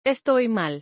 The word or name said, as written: Estoy mal.